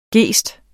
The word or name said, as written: gest